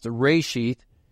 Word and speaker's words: reshith: In the beginning is translated from one Hebrew word pronounced reshith,